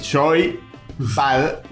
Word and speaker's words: Joypad: Y entre paréntesis, ¿cómo se pronuncia? Dice Y-O-I espacio P-A-D. Joypad.